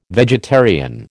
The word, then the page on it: vegetarian: American English